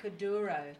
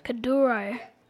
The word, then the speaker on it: Kuduro: Kuduro. – Kuduro.